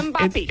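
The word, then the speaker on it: Mbappé: Yeah, Mbappe. – Oh yeah, Mbappe.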